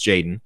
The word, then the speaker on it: JXDN: the JXDN, it's pronounced Jaden.